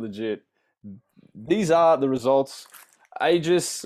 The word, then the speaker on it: Aegis: I think it's. Is it Aegis? Ages? Is it Ages? – Aegis. – That's what I'm going to go with. It is AYEEGUS. If you know how to pronounce that.